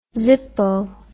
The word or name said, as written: zippo